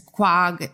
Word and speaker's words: Quogue: It's spelled Q-U-O-G-U-E. – Quag. No, well, it makes sense, I guess. – Do you know where Quag is? – No, where?